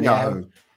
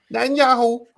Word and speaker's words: Netanyahu: Netanyahu. – Netanyahu.